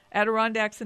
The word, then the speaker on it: Adirondacks: In North America, the most popular places are the Adirondacks, to say that word, because it doesn't look like how it's spelled. Adirondacks in New York,